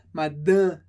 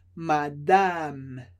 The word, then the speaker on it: madame: Importante que o segundo A, ele não é nasal. No português a gente falaria madame, madame. Em francês, a gente diz madame, madame. Duas vezes o som A, porque a sílaba fica separada. Então, de novo, madame.